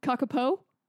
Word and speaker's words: kakapo: And the cockapoos. – Hold on, I might be saying it wrong. There's only one O. Kakapo? Yeah. K-A-K-A-P-O.